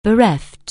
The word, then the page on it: bereave: anglais